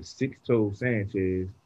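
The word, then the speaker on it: Sixto Sánchez: Uh, six toe Sanchez. I know that's probably not pronounced right, but that's what it's spelled. – No, it is right. You got it right.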